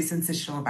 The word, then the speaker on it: syncytial: Am I pronouncing the name of this virus properly? Respiratory syncytial virus? – Firstly, good evening and thank you for having me. You're actually, yeah, that's actually how you pronounce it.